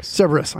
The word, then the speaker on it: Cerberus: Yeah, how do you say it? Cerberus? – Cerberus.